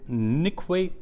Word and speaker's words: Nkwe: And the one company is Nikwe Platinum. The name is spelled as NKWE Platinum.